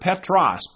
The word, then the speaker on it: Petros: And that name, Peter, in the Greek, it's the word Petros, P-E-T-R-O-S, if you spell it in the English form, which means a little stone, a little stone.